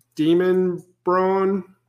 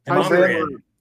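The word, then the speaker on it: Demonbreun: Is it Demon Braun? Brune? – Demumbrian. Demumbrian. Demumbrian. – It is not. – Yeah. – It's Demumbrian. – Demumbrian. Really? Yeah. I always thought it was like demon something.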